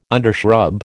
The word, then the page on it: undershrub: American English